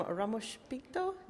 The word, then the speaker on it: Ramos Pinto: Okay, it's the Wine Sleuth, and I'm here with Anna Rosas from Ramos Pinto. Is that how you pronounce it? – Exactly, very good accent.